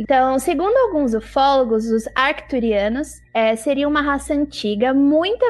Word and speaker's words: Arcturianos: Olha, é Arcturianos com T. – É com C? Esse C é pronunciado? Eu achei que o C não é. – Acho que sim, no MIB era.